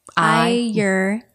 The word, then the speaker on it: Ayurveda: Ayurveda. – Ay as in your eyeball.